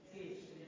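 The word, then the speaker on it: Cif: Sif? Is that how you pronounce it? – Sif.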